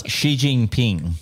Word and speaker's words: Xi Jinping: How do you pronounce the Chinese president's name again? – Xi Jinping.